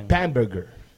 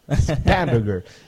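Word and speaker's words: Spanberger: Y dio cara a que Trevor Spankenberg. ¿Usted que lo dije bien? – Spamberger. Spamberger. Es como una mezcla entre hamburger y spam.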